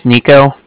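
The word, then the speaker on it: Niko: My name's Nico. That's N-I-K-O. I was calling with the Bank of Cardiff, and Cardiff is spelled C-A-R-D-I-F-F, as in Frank Frank, so it's a car and then different.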